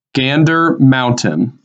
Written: Gander Mountain

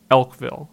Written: Elkville

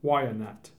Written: Wyanet

